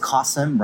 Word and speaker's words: Qasim: And the way I learned how to pronounce his name is Qasim rhymes with awesome.